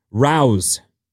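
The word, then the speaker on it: Rao's: It's pronounced as it's spelled. Rouse. – That's how you. – Like a rouse.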